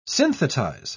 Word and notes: synthetize: British English

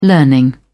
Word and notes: learning: British English

